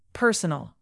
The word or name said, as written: personal